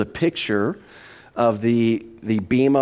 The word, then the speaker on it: Bema: Remember, this judgment this Bema or Bema is really how it's pronounced, but I'll go back and forth.